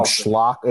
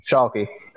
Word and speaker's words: Schalke: He plays on, I'm going to probably butcher this team, Schlock. It was the, what was it? – I already know that you're going to pronounce it terribly, so I'm helping you out here. – Schalke.